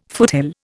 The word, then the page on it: foothill: Indian